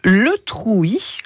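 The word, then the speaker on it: Le Trouit: Jacqueline, son nom de famille, c'est du coup Le Trouy. Oui, on prononce ça comme ça.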